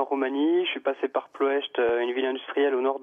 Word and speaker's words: Ploiești: Ouais, on ne prononce pas le i final en roumain, j'ai appris ça. Donc c'est Ploiești ou Ploiești quand même.